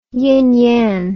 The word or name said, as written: ying yang